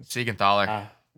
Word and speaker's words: Siegenthaler: Siegenthaler.